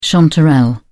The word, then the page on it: chanterelle: British English